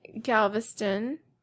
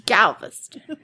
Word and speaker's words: Galveston: Oh, anyway, um, so, uh, yeah, and then I went to Galveston with the hard G. Galveston.